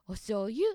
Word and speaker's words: お醤油: あ、面白いアクセントですね。お醤油って終わらなくて、お醤油とうがつくところが面白いなと思いました。